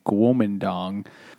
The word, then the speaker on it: Kuomintang: China's government at the time, you could say, was the Kuomintang, which is now pronounced the Kuomintang.